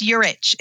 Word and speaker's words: Urich: So it's Urich. It's like church.